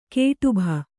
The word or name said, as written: ಗಡುಬ